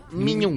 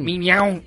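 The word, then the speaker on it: mignon: ¿Cómo se pronuncia? ¿Lo pronuncie bien? – A ver, otra vez, repíteme. – Minion. – Minion. – Sí, así o como... – ¿Por qué maullas? – Miñón. – Miñón.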